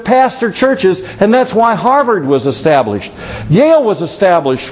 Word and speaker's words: Harvard: Harvard. Silent R. Harvard.